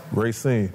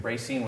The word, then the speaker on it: Racine: Racine. – Racine, Wisconsin.